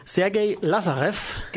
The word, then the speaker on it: Sergei Lazarev: Hier sind wir wieder und das war jetzt, ähm, Dimitri hat mir es gerade gesagt, zack, ist es richtig, ähm, Sergei Lazarev. Genau, völlig richtig ausgesprochen.